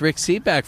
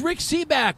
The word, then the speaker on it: Rick Sebak: And we're going to be day drinking with Rick Seaback, for God's sake. This is a cool thing. – Say it right. God. – God, yeah. It's Rick Seaback.